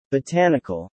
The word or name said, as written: Botanical